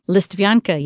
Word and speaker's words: Listvyanka: And then there's a place called, forgive my pronunciation, Listvyanka? – Listvyanka, yeah.